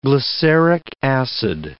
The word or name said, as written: glyceric acid